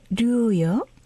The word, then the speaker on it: 류: 네, 트루안도트의 류요? – 예. – 네. 제가 발음이 맞습니까? – 예, 류 맞습니다.